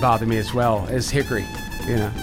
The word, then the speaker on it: Hickory: Hickory! You got to say it like that.